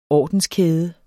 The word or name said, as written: ordenskæde